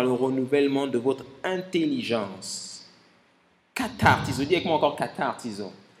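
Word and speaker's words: katartizo (καταρτίζω): Le mot perfectionnement, pour ceux qui n'étaient pas là, c'est un mot grec que l'on prononce katartizo.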